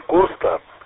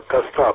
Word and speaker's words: Коста: Только это, вот что интересно, вот Коста Хитогоров, это писатель остинский, не Коста, а Коста просто. Коста Хитогоров, это писатель. Ну, кто не знает, в основном почему-то ударение делают на первый слог Коста. А правильно, Коста просто.